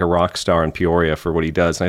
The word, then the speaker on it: Peoria: Is that how you say it? – Peoria.